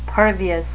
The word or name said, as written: pervious